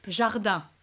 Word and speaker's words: Jardin: Except you don't pronounce the N in the end, so that's where people always get it wrong. It's Valérie Jardin.